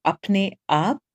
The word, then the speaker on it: Apne Aap: And we call the NGO Apne Aap. It's pronounced a bit like up and up.